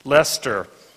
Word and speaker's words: Leicester: Leicester, in England.